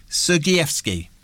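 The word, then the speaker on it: Sergievsky: So first of all, tell us a little bit about your character, which is Anatoly, and I hope I pronounced this right, Sergeyevsky. – That's spot on.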